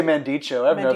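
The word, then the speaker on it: Mandico: Do you not pronounce his last name? – I just say Mandicho. That's correct.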